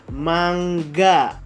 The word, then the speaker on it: manga: Manga itu sebetulnya dibaca dalam pelafalan bahasa Jepangnya atau pengucapannya atau pronunciationnya itu dibaca mangga. Jadi ingat ya, manga dibacanya dalam bahasa Jepang itu mangga.